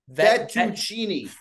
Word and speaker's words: fettuccine: Veniccini bolognese. – No, not ven. Not ven. – Fettuccini. – Fettuccini bolognese. – Fettuccini. – Fettuccini bolognese. – No, fet. Fet.